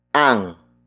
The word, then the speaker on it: ang: The next word is ang. Ang. Ang. Ang is spelled as A-N-G.